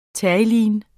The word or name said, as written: terylene®